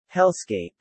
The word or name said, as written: hellscape